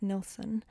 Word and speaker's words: Nilsson: but only when Harry Nilsson, or Nilsson, I guess it's pronounced Nilsson, recorded it and released it, did it reach success on the Billboard charts in 1969.